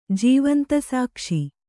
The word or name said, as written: ಜೀವಂತಸಾಕ್ಷಿ